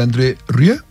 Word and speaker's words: André Rieu: Fuera del micrófono, usted me contó una anécdota muy simpática del señor André Rieu.